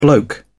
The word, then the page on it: bloke: British English